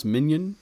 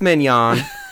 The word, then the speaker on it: mignon: you sure it's not pronounced minion? – No, she actually corrected me. – It's mignon, like the steak.